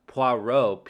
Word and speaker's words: Poirot: Poirot, P-O-I-R-O-T.